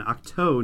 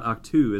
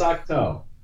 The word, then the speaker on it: Octo: So that is the correct pronunciation then. Octo, not Octu, as I've been saying, correct? – I think so.